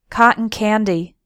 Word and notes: cotton candy: American English

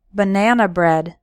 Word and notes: banana bread: American English

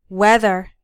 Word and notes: whether: American English

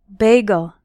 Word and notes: bagel: American English

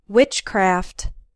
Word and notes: witchcraft: American English

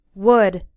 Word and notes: wood: American English

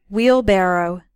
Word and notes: wheelbarrow: American English

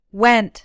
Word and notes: went: American English

